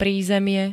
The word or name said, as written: prízemie